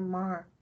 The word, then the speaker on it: Maher: Mayer? John? No. Bill Mayer. Bill Maher. – Bill Maher. That's true.